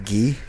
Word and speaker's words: Guy: Wouldn't that be pronounced Guy? – You're right, Guy LaFleur.